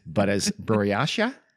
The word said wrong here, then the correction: Buryatia: B U R A, I'm sorry, B U R Y A T I A. Want me to do it real quick? Our story takes place in a place called Buryatia.